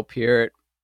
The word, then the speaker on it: Peart: Because it looks like Pert, man. Who says Peart? Yeah. Peart is stupid. But now that he's dead, I say it right.